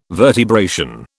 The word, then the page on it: vertebration: British English